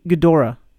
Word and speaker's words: Ghidorah: Giradora, honestly, coolest like oh no it's God- G- Ghidorah.